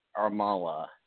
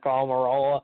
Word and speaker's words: Almirola: And that's Joey Logano, Brad Kolaski, and Eric Almirola. And I mispronounced that name, and I had it backwards, so straighten me out there. – Eric Almirola. – There we go.